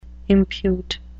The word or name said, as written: impute